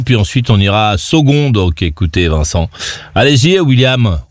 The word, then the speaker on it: Saulgond: Vincent, 27 ans, Sogon. – C'est où ça, Sogon ? – C'est en Charente. – Sogon ? – Sogon. – Saumon ? – Sogon. – Sogon, ça s'écrit comment ? – S-A-U-L-G-O-N-D. – S-A-U-G-O-L-B ? – G-O-N-D.